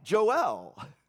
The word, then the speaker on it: Joel: You can say Joel. I like Joel. Rhymes with Noel.